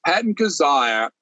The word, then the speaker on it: Kizzire: Patton Kizzire. Did I say his name? – Yes, Patton Kizzire.